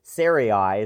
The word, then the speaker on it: Sarai: The name of Abram's wife was Sarai, is the way I tend to pronounce it. I've heard other versions.